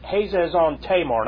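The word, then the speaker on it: Hazezon-tamar: And that Hazez-on, I would pronounce it Hazez-on-Tamar.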